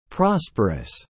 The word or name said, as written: prosperous